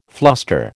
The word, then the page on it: fluster: American English